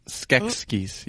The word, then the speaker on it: Skeksis: You sounded like one of the Skeksis. Skeksis. Yeah, that's how you say it. It's a hard word to say.